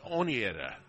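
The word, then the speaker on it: Oneida: Ryan, a 6'3" senior from Winnetta, New York. I'm saying that right? Winnetta? – That might be Onietta. – Onietta? – But I'm not sure. – Okay, I would think that would be right. So we got people listening in New York right now.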